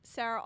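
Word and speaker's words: Sara: I am your evening host, Sarah Ald.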